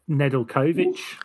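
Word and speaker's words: Nedeljković: Glad you, how would you say it? – Costa Nedeljkovic. – Good.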